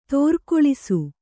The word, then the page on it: ತೋರ್ಕೊಳಿಸು: tōrkoḷisu